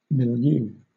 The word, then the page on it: milieu: British English